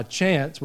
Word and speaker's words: Chance: And none of you call me Bill because you know me by Chance, which is my common name. And if you see how it's spelled without an E, but you don't know me that well, you'll call me Chank, right?